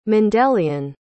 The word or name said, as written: Mendelian